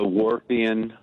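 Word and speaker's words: Whorfian: The Warpian hypothesis. – Nope, not. Thank you for it. How do you spell that? – W-H-A-R-F-I-A-N hypothesis. – Got it. Like, is it a fisherman's wharf? Yeah, Benjamin Wharf is the postulator, or the person who postulated.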